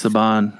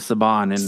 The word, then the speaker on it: Saban: I might be calling it wrong, but everywhere I hear it pronounced as Saban, and watching other people that are in Power Rangers talk about it, they say Saban.